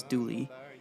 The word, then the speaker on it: Dula: The song is based off of a true story involving a man named Tom Dula, spelled D-U-L-A, though it's pronounced locally as Dooley, as well as a woman named Laura Foster and some secondary characters.